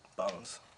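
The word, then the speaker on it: Baunz: Yeah, so my first name is Bo. I spell it B-A-U, so I just decided to add N-Z at the end and just pronounce it the way I wanted to, bones.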